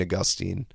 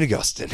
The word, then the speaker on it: Augustine: But it seems as though some of the oldest Christian groups, when speaking in English, would pronounce it Saint Augustine.